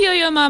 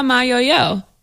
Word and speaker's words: Yo-Yo Ma: You call Yo-Yo Ma Mayoyo. Is that how we should say his name? – Yes, because I mean, his last name is Ma. – And you say that first, usually.